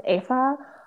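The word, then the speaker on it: Aoife: And the first kid's name is I think it's called pronounced Afa, which I think is like literally the cutest, Irish. – Yeah, I think it's like the cutest name ever. It's like spelled A-I-O-F-E.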